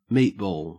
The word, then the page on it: meatball: Australian English